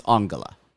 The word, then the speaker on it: Angela: It's spelled Angela, but it's pronounced Angela.